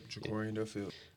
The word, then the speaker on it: JaCorian Duffield: Yep, JaCorian Duffield.